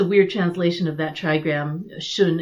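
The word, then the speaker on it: Xun: So hexagram 57, Sun, or Shun, I think is actually how you'd pronounce it, because in modern transliteration, it's X-U-N.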